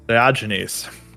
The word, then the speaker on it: Diogenes: I was going to ask you how to pronounce his name. Diogenes? Diogenes.